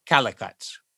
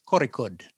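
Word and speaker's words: Kozhikode: And that's where they get to Calicut, which is pronounced Korikud, but they call it Calicut in this book.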